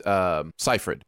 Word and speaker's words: Seyfried: I will say it is Amanda Seyfried.